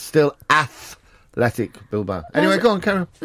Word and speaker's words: Athletic: Well, you're saying it wrong. Because it's Atletico Madrid, Atletico Zaragoza, but Athletic Bilbao, because it was started by English people.